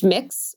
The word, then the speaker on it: Mx: So you could use MX, and that's pronounced mix, whenever you would like to do a formal introduction to someone.